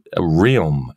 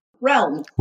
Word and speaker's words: Realm: She lives in Jackson Hole, Wyoming, and is the founder of R-E-A-L-M. This is Realm. How do you pronounce it? – Realm. – Realm, of course.